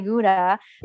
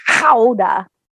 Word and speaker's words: Gouda: And Eddie loves to correct everybody when they say Gouda, because apparently the real pronunciation is Howda.